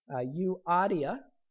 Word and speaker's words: Euodia: So here's a pronunciation. I'm doing my best. Euodia.